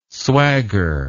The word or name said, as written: swagger